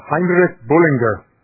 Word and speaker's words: Heinrich Bullinger: This is, I think it is pronounced Heinrich Bullinger. The G may be hard, but we'll call him HB.